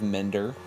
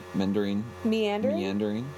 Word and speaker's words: meandering: One, musical interludes that kind of mender, which are my favorite part. – They're kind of mendering. – Meandering.